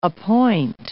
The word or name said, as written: appoint